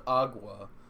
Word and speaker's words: Agwa: A G W A, pronounced agua.